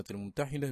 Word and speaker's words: الممتحنة: قال القرطبي: والمشهور على الألسنة النطق في كلمة الممتحنة بكسر الحاء، وهو الذي جزم به السهيلي.